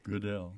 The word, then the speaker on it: Goodell: Among his books is A Winter with the Mormons, the 1852 letters of Jotham Goodall. Did I pronounce that right? – Goodell. – Goodell.